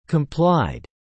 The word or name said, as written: complied